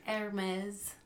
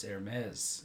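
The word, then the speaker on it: Hermès: Hermes. – Oh, it's Hermes.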